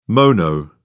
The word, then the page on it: mono: British English